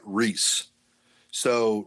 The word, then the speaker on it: Reese's: Harry Burnett Reese. So Reese himself said the correct pronunciation is actually Reese's.